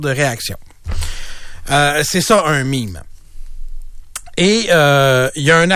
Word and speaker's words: meme: Bon, je vous explique le terme meme, M-E-M-E, ça s'écrit comme meme, mais c'est en anglais.